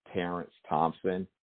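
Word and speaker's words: Terence Thompson: My social media platform that you can engage with me mostly would be on Facebook at Producer Terrence Thompson. And my name is spelled T-E-R-E-N-C-E Thompson, T-H-O-M-P-S-O-N.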